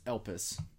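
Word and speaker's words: elpis (ἐλπίς): That Greek word used in 1 Thessalonians 5:8 it's pronounced elpis.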